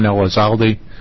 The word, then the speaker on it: Elizalde: Chairman Richardson and committee, I'm John Elizalde, and that's spelled E-L-I-Z-A-L-D-E.